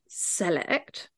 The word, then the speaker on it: Select: which is spelt like select, but pronounced Select.